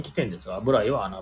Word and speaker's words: BURAI: その中でも私が一押ししたいのはブライえー、これはですね、アルファベットで BURAI と書いてブライですね、うん、えー、住宅街に突如現れる隠れ家で、おしゃれ静かな、えー、焼き鳥店です。